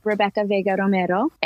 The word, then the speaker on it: Rebbekah Vega Romero: um, I say Rebbekah Vega Romero.